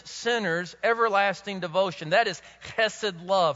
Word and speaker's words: chesed: Now, in the Hebrew world, there's a word for this loving, loyal kindness, and it's called chesed. You might spell it H-E-S-E-D.